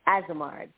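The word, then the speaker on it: Azemard: Azimard.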